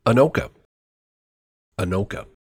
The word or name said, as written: Anoka